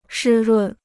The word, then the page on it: 湿润: shī rùn